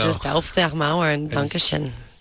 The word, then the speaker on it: Auf der Maur: So am I saying it right? – Oh, yes. – Auf-Demar. It's actually Auf-Demar is a little better, but that's fine.